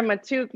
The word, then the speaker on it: Maatouk: And then Sarah Matouk, my last name is M-A-A-T-O-U-K.